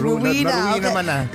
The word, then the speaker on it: Rowena: Rowena. No, dito kasi sa US po, ang Rowena is not Rowena. We pronounce it as Rowena.